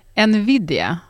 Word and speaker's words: Nvidia: Nvidia.